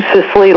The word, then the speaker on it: Sisley: My name is Sue Sisley. Last name is S-I-S-L-E-Y.